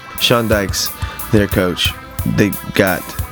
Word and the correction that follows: Dyche: Did I say that right? – Not quite, but that's all right. – Well, we'll correct me. – Dyche? – Yeah.